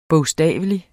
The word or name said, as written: bogstavelig